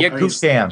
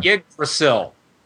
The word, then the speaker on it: Yggdrasil: That's definitely, I can't even pronounce that name, the world tree. – Oh, Yggdrasil. – Yggdrasil.